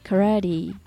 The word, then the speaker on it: karate: skiing go snowboarding go skateboarding go surfing みたいな感じですねあとは動詞に do を一般的に使うものもいくつかあります do karate karate っていうのは空手のことです全然違うものに聞こえちゃいますよね英語っぽく発音すると karate と発音されます